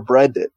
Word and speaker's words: Brenden: my name is spelled B-R-E-N-D-E-N. That's for Brendan.